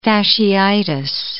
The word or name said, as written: fasciitis